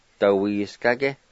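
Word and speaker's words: dawisgage: Number two, slick, dawiskage.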